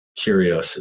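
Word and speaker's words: Kairos: Kyrios. Is that how you pronounce it? – Yeah, dude, it's exactly like what we were saying with language, that Kronos is the language and Kyrios is the feeling.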